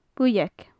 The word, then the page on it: buidheag: gaelic